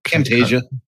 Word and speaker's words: Camtasia: Camtasia.